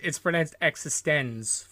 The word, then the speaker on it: eXistenZ: and that's Existein Z, which is not. – I think it's pronounced Existenz. – I thought you were going to say Existen Z. – No, just Existenz, because I saw that in the cinema.